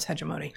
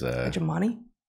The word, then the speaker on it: hegemony: Which I would pronounce differently, but that is... – Hegemony? – Hegemony. – Hegemony. – Hegemony. – Oh, right. I turned it into the noun form without correcting where the syllable went.